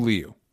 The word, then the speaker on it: Liu: But I will say, we are confirmed it's Simu Liu. – Yeah, like L-I Liu. – Cool. – A quick U at the end, Simu Liu.